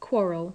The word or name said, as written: quarrel